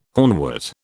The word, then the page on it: hornwort: British English